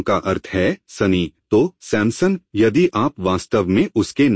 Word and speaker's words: सैमसन: सैमसन का परिचय अब शिम शोन, आप में से कितने लोग सैमपसन शब्द का उच्चारण करते हैं? इसमें पी के साथ सैमपसन, हम इसका उच्चारण पी से करते हैं। क्या सैमसन में कोई पी है? असल में आप जो कर रहे हैं वह ग्रीक उच्चारण का उपयोग कर रहे हैं। ग्रीक में पी है और यह अंग्रेजी में आ गया है।